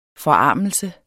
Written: FORARMELSES

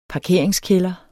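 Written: PARKERINGSKÆLDER

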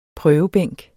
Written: prøvebænk

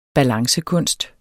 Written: balancekunst

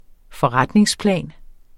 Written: forretningsplan

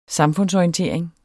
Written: samfundsorientering

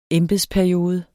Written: EMBEDSPERIODE

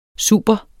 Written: super

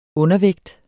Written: undervægt

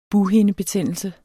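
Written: bughindebetændelse

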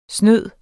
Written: snyder

